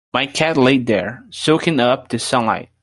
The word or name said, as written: soaking